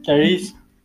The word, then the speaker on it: Tyrese: entre este tires Tyrese, no sé cómo se pronuncia, tires, tires. – Tires Gibson.